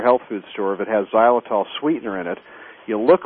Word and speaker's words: xylitol: And that's something called xylitol, and it's spelled with an X. It's X-Y-L-I-T-O-L.